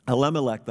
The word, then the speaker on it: Elimelech: But Elimelech, believe it or not, that's how you pronounce it. Elimelech, the husband of Naomi, died, and she was left with her two sons.